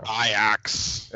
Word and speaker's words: Ajax: Sergino Dest, who is currently at Ajax, or I think it might be pronounced Ajax, I don't know. – Ajax.